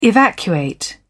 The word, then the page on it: evacuate: British English